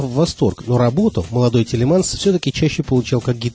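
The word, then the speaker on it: Thielemans: которого весь мир знает под именем Тутс Телеманс. На самом деле, его полное имя Жан-Батист Фредерик Исидор Телеманс. В Америке его фамилию произносят как Тилманс, а Тутс – это прозвище, которое молодому музыканту дали приятели в послевоенной Бельгии, чтобы его имя звучало как-то более джазово.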